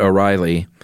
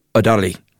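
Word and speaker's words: O'Rahilly: And his name is Ronan, and I've heard Americans pronounce it O'Reilly. – Yeah. – It's spelled O, little accent, capital R-A-H-I-L-L-Y. And I heard him speak his name in court when they asked his surname, but he said it so quickly, it sounded like O'Dolly. It sounded like a D, so it may just be like some weird, like, you know, Irish pronunciation or something that I don't know about. – Sure. – But we're going to say O'Reilly.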